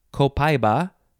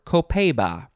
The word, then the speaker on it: copaiba: You could pronounce it a couple of ways. Copaiba or Copaiba. C-O-P-A-I-B-A.